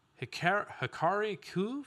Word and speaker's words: Hikari Ku: Hikari Ku from Octopath Traveler 2. Did I say that right, Josh? – Yes, you did.